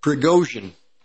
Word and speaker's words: Prigozhin: Yeah, I think I pronounced it correctly. No, I didn't. Prigozhin.